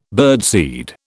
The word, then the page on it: birdseed: British English